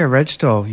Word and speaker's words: Rejto: Her name is Nika Rejto. You pronounce it, it's actually spelled R-E-J-T-O.